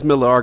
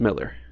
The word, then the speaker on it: Millar: Everybody says Millar because it's got an A instead of an E, but it's pronounced Miller.